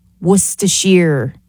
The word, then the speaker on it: Worcestershire: And also, Worcester sauce, Worcestershire is how you pronounce it.